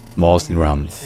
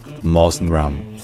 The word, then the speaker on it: Mawsinram: Par contre en anglais, donc l'autre langue nationale de l'Inde, ça se dit Mawsinram. Mawsinram.